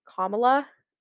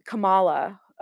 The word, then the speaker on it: Kamala: like if you just say comma and then add la at the end of it, Kamala, that is the correct way of pronouncing her name.